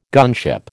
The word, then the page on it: gunship: American English